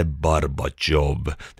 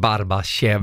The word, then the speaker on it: Barbashov: Bodens stolthet som tillsammans med Barbashov. Jag säger det nu också. På ryska uttalas det Barbashov. Det är därför jag har gjort det.